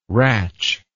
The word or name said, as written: ratch